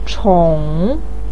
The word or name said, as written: chong3